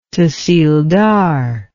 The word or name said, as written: tahsildar